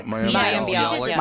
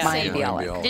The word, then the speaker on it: Mayim Bialik: Mayim Bialik. – Mayim Bialik.